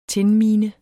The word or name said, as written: tinmine